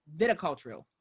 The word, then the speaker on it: viticultural: Usual Wine is made in the American viticultural area. No, it's viticultural. I didn't add so many other syllables.